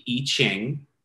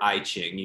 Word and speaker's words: I Ching: Um, the I Ching is, or I Ching, you hear both pronunciations, are um, it's huge in this.